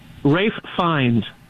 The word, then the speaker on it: Ralph Fiennes: Ralph Fiennes. – Ralph Fiennes. – Ralph. Now, of course, it's spelled Ralph, yeah. But the English, you know.